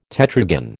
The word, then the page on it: tetragon: American English